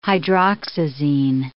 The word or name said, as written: hydroxyzine